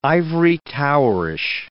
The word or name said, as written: ivory-towerish